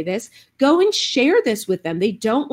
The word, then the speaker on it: Shair: Austin, Omar is the badass Costa Rican host of the Share podcast, and that is spelled S-H-A-I-R. So don't get it confused with what you might think is the spelling.